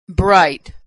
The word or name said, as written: bright